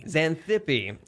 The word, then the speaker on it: Xanthippe: Xanthippe? Is that how you pronounce it? – Xanthippe. – Okay.